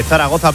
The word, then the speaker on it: Zaragoza: Ahora sí lo digo bien, de Zaragoza,